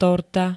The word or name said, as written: torta